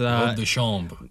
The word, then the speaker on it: robe de chambre: Robe de chambre.